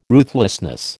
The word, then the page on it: ruthlessness: American English